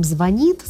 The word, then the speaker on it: звонит: Вот мы сейчас наблюдаем процесс, как смещается ударение в глаголах. Предположим, мы с вами говорим «звонит», «звонишь», «звоним», «повторит», «включишь», «включим свет», «включим камеру». Но мы видим, да, что уходит ударение, там «солит», «сверлит», а кто-то и звонит, говорит, и удивляется, говорит, да ладно, никто не говорит «звонит», это с кем вы там общаетесь? Вокруг меня все говорят «созвонимся». И ты каждый раз думаешь, могу ли я сказать «звонит» или все-таки пока еще не могу.